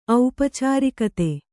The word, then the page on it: ಔಪಚಾರಿಕತೆ: aupacārikate